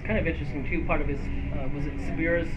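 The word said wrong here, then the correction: Tsuburaya: am I saying his name right? – Subaraya. – Thank you.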